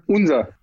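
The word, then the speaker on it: Unzer: Wie spricht man diesen Namen eigentlich aus? Unser oder Junzer oder Anser? – Unser. – Unser? – Ja. – Okay. So ich hatte mich überlegt, wie klingt wie Uber bloß mit Z? – Nee, also das ist Claudia, unsere Marketing-Chefin.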